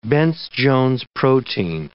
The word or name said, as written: Bence-Jones protein